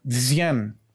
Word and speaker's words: Dzyan: Según Blavatsky, el libro de Decian, se escribe D-Z-Y-A-N, Decian,